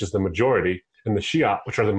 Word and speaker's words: Shia: So I was raised as a Muslim, as a Shiite Muslim. And the proper way to say it is Shia.